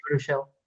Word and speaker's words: Baruchel: Is it like Jay Baruchel or whatever this fucking Baruchel. – Is? – Yeah. – Jay Barracuda. – Yeah. Isn't he what? You were saying, isn't he Jay Baruchel?